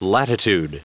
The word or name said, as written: latitude